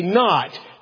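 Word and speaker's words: knot: Kind of like RK on the word not. We don't say cannot, we say not. The K is just there for no apparent reason.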